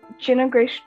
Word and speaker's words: Jenna Grace: Well, I have an Etsy page under the name Jenna Grace Jewelry. And my name is spelled J-E-N-N-A-G-R-A-C-E Jewelry.